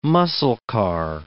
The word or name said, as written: muscle car